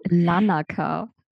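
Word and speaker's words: Larnaka: Denn im Augenblick lebst du gemeinsam mit deinem Mann am Strand in Lakana. Ich hoffe, das ist richtig ausgesprochen. – Lanaka. – Lanaka, okay, mhm.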